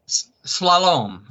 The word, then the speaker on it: Slalom: Slalom, is that how it's pronounced? – Slalom. Slalom. – Slalom, okay.